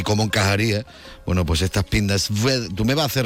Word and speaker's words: Pinda Sveda: Se dice Pinda Sveda. – Sveda. Ah, la W como si fuera una B, ¿no? – Es sí, de hecho, a veces se escribe con una V, Pinda Sveda, S-V-E-D-A.